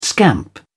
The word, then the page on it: scamp: British English